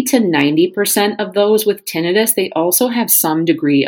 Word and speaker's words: tinnitus: Today I want to talk about tinnitus, or tinnitus as it's sometimes pronounced.